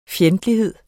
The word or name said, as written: fjendtlighed